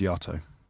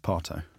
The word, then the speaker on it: Yato: By the way, it's Yato. It's not Pato. It's Yato. – Well, it might be if you're as posh like you. Yato. – It's spelled Y-A-T-O.